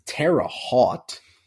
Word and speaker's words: Terre Haute: Um, but as we head to the Midwest part of the country in Terre Haute that's how, that's what I decided on how to say it.